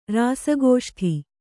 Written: ರಾಸಗೋಷ್ಠಿ

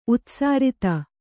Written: ಉತ್ಸಾರಿತ

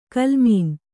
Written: ಕೋಲುಮೀನು

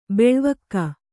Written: ಬೆಳ್ವಕ್ಕಿ

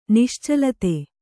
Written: ನಿಶ್ಚಲತೆ

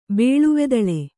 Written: ಬೇಳುವೆದಳೆ

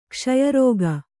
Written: ಕ್ಷಯರೋಗ

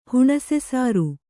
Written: ಹುಣಸೆಸಾರು

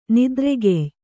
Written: ನೆತ್ತರುಗೆಯ್

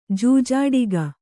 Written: ಜೂಜಾಟಿಕೆ

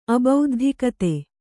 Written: ಅಬೌದ್ಧಿಕತೆ

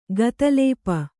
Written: ಗತಲೇಪ

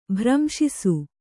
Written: ಭ್ರಂಶಿಸು

